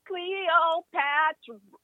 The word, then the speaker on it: Cleopatra: Cleopatra.